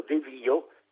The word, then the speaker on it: devio: Ha ragione, perché in questo verbo «deviare» l'italiano sente la presenza del sostantivo «via», la pronuncia buona è quindi «io devio» e analogamente «io avvio», «invio» e «rinvio» e «ravvio», «ravviati i capelli» e anche «io ovvio» e «io travio».